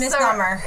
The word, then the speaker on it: misnomer: I think I said that wrong. – Yeah, you did. – I know I said it. – Misnomer. – So, misnomer. I said misdemeanor. I was going to say misdemeanor.